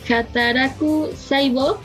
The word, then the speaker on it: Hataraku Saibo: es Hataraku Saibo.